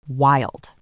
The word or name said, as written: wild